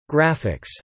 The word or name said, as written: graphics